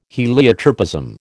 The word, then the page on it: heliotropism: American English